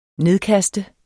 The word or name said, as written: nedkaste